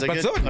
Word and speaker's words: Bad Soden: My daughter's going to go back to Bad Soden. I hope I say, am I saying it right? – Yes, Bad Soden.